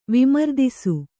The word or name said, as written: ವಿಮರ್ದಿಸು